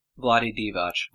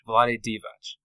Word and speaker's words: Vlade Divac: Vlade? Is that how it? Vlade Divac? – Vlade Divac. – Vlade Divac.